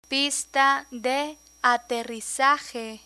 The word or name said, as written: pista de aterrizaje